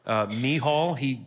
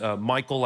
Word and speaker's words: Michal: Then Saul tries to sabotage David by marrying him off to his daughter, Michal. Savut used Michael last week, which is another proper way to say it, but I'm going to say Michal because I just want to be different.